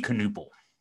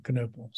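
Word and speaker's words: Knueppel: It's a German last name, and if we're going with the German pronunciation, it would be Knupel. – Okay, Knupel.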